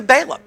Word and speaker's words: Balaam: And then Jude turns his attention to Balaam. That's how we pronounce it. It was probably Balaam in Hebrew, but I'm going to continue to say Balaam because I couldn't change the habit if I tried.